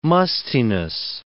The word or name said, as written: mustiness